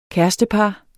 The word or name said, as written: kærestepar